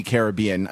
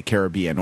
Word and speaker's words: Caribbean: the correct pronunciation of things, that they actually say Caribbean, or that many of them do, so that it is not more correct to say Caribbean.